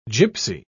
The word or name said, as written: gipsy